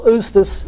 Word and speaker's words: justus: And this translates, simul justus et peccator, J's are pronounced like an I in Latin, that the believer is simultaneously just and a sinner.